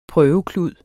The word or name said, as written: prøveklud